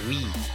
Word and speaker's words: V: 嗯，然后呢，再给你们讲个新闻啊，这个，这个那个啥哦，这个我看看啊，诶，哦对，是的，那么这个 Maroon Five 这个新的专第五张专辑啊，这第五张专辑名字叫做 We。其实也可以读作 Five，因为他们这一个专辑的这个 V，其实就是代表他们第五张专辑而已，就是代表他们第五张专辑。